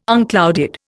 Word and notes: unclouded: Indian

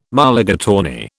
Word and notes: Mulligatawny: British English

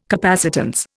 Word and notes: capacitance: Indian

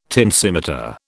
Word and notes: tensimeter: British English

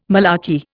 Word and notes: malarkey: Indian

